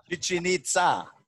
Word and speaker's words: Chichen Itza: Chichen Itza, it's a Mayan word in Mexico.